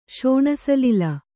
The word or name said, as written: ಶೋಣಸಲಿಲ